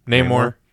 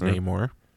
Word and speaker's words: Namor: Namor, I guess you should pronounce it. Mm-hmm. I like that we got a nice pronunciation of it. So we'll see how many people that just don't even know anything about the guy will now pronounce it like that versus Namor.